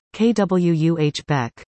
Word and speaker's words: Quebec: The correct pronunciation for non-natives is Quebec, while French speakers would say Quebec.